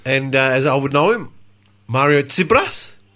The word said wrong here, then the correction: Tsirbas: And it's spelled T-S-I-R-B-A-S. And in Greek, the pronunciation of the T-S is ts, which seems to be a little bit difficult for the Aussies.